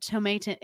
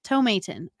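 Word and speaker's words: Tomatin: So just to start kind of going into the distillery background and history here, the Tomaten is, I'm hoping I'm saying that, Tomaten, it's a long A.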